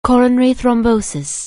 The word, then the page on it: coronary thrombosis: anglais